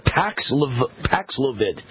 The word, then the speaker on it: Paxlovid: I can never remember how you pronounce it. Hold on a second. I think that's it. Yeah, Paxlovid. Paxlovid is the drug Pfizer came up with to fight COVID, and it doesn't work.